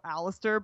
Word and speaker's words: Aleister: They started to say, "Let's go Aleister," but then they realized that it's three syllables.